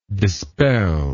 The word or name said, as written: dispeller